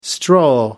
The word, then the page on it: stroll: American English